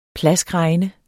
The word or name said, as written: plaskregne